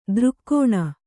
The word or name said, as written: ದೃಕ್ಕೋಣ